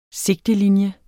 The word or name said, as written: sigtelinje